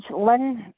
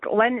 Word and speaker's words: Lynn: it's Lynn Frances Moan. The Moan, Lynn is spelled LYNN, and Frances, and Moan is MONE.